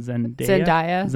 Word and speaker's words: Zendaya: So, Zac Efron was quoted at his movie Kiss with, I'm going to butcher her name again, Zendaya.